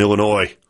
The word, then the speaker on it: Illinois: It's funny, I saw a video with those guys where they pronounced the S in Illinois.